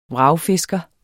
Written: vragfisker

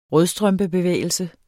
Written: rødstrømpebevægelse

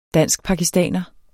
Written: danskpakistaner

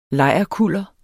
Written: lejrkuller

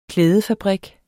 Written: klædefabrik

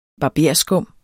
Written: BARBERSKUM

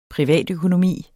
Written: privatøkonomi

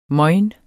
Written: mojn

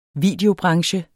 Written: videobranche

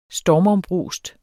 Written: stormombrust

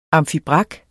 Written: amfibrak

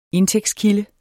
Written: indtægtskilde

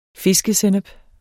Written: fiskesennep

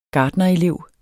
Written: gartnerelev

